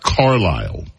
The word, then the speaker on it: Carlisle: It's not Brenda, it's not Carlisi. The Countess of Conspiracy sent me a note. Excuse me. Her name is pronounced Carlisle.